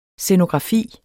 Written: scenografi

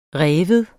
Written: rive

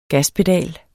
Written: GASPEDALEN

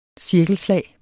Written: cirkelslag

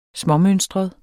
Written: småmønstret

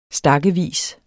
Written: stakkevis

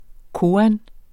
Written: koan

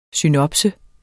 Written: synopsis

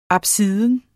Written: apsis

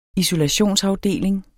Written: isolationsafdeling

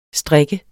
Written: strik

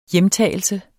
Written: hjemtagelse

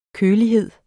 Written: kølighed

